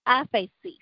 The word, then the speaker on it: aphesis: Aphesis.